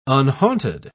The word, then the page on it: unhaunted: British English